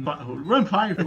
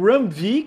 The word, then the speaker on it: Ram V: É foda, porque esses caras colocam numa gente fica em dúvida, porque tem o Ram V que pronuncia que nem V, mas tem o James Tinion the Fourth.